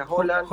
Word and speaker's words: Haaland: Y ahí está el empuje que tienen ellos, como Haaland, bueno, ustedes dicen Haaland, pero se pronuncia Haaland.